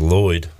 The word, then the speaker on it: Lloyd: My middle name is Lloyd. How would you spell that? – L-L-O-Y-D.